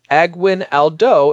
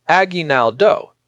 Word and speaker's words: aguinaldo: It'll pronounce it as aguinaldo instead of aguinaldo.